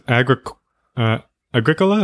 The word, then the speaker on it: Agricola: Next up is Agricola, is that how you pronounce it? Revised edition. – Yeah, so it's funny.